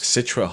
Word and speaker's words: Citra: Citra hops.